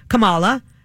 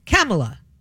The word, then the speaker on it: Kamala: It used to be Kamala, and now it's Kamala, like Pamela with a K.